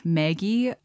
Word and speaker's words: Maggi: Now, I don't know if you've heard of Maggi. I think I remember it being pronounced Maggi, like growing up. M-A-G-G-I.